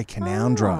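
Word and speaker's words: Canowindra: I don't know if you guys have probably seen Canoundra. Written down looks like Canawindra. – Oh, yeah, Canoundra. Oh. – Canoundra yes. – looks like Canawindra.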